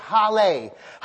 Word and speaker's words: halal: It's spelled H-A-L-A-L, and it's pronounced Hale. Hale.